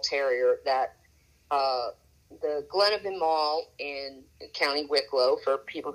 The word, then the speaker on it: Imaal: And then Imaal, Imaal, is pronounced like, where would you get one? Well, you would get one at a mall if they ever sold them there, which they don't, but it's pronounced sort of the same way.